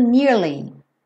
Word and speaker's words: nearly: Tem que ter esse Y no som do nearly. tá? É uma palavra de duas sílabas, nearly.